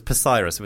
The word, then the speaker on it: Psyrus: We were called Cyrus with a silent P. At the front, it was Psyrus.